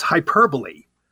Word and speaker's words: hyperbole: H-Y-P-E-R-B-O-L-E, which, you know, obviously excessive speech. A lot of people pronounce it hyperbole. And why do they pronounce it hyperbole? For very good reasons. For that exact test I just gave you, B-O-L-E should be pronounced bowl, not bull.